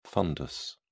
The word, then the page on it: fundus: British English